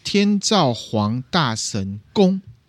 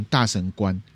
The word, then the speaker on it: 天照皇大神宫: 天照皇大神宫。可是大家呢有时候会念成大神官，可是其实它不是官。伊势神宫里面就是拜天照大神，还有什么御神体八子镜，因为八子镜就是天照大神的代表物。